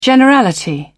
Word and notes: generality: British English

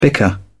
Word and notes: bicker: British English

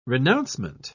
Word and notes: renouncement: British English

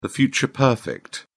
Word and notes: future perfect: British English

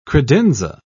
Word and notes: credenza: British English

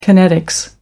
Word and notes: kinetics: American English

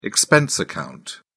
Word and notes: expense account: British English

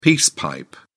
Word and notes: peace pipe: British English

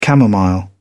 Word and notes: camomile: British English

